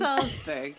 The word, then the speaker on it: Pfalzburg: And one of the examples in one of the ballads said that his gang gathered in Fallsburg, which is spelled P-F-A-L-Z-B-U-R-G. So the P is silent, I think, but it could be Pfalzburg. And Fallsburg.